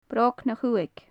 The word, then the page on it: brog na chuthaig: gaelic